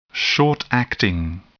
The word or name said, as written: short-acting